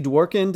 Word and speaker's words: Dworkin: All right, our first guest today is David and Wendy Dworkin.